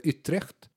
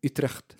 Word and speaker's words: Utrecht: Och, men sen blir han ändå värvad av Utrecht. – Tack för uttalet. – Det var ju bra ju. – Vill du säga det en gång till? – Utrecht.